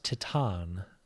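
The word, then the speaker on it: Titane: I believe it's pronounced Titan, but okay, no, I have not.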